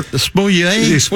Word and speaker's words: Smollett: What's the pronunciation on that? – Jesse, what's all, whatever. – Smollett. What is it, Luce? – Juicy Smollett.